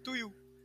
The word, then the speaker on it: Tuyu: To You. – How do you spell it? – T-U-Y-U.